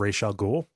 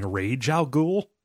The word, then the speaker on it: Ra's al Ghul: We got some feedback about our pronunciation of Ra's al Ghul. We were praised for getting it right, but over the course of the episode, we eventually started saying Rage Al Ghul.